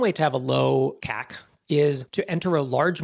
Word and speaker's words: CAC: It's often pronounced CAC.